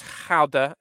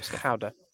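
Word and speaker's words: Gouda: It's actually pronounced Howda. Um, if you learned nothing from Stefan de Jong, last year's uh, league winner. Sorry, Howda.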